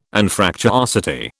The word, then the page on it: Anfractuosity: British English